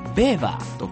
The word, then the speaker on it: Weber: 名字にあたるこのファミリーネーム、あのスペルがですね、あの WEBER、あのウェーバーなんですけども、あのプロイセンおよびドイツの出身なので、ドイツ語発音するとベーバーと濁ります。